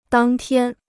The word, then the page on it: 当天: dàng tiān